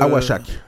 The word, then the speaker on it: Awashak: Awakak, on a naissance d'une organisation à la recherche des enfants autochtones perdus, c'est-tu... – Awashak. Le C en Atikamekw se prononce comme un CH.